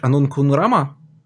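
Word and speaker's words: Анунг Ун Рама: Ну, а, значит, он тысячи лет торчит на одном месте с одной масштабной задачей убить, э, блин, ты всегда эти слова правильно произносишь. Анун Кунрама? – да.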